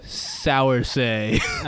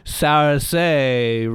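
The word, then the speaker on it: Saoirse: Sourcey. – Uh-huh. – Sourcey.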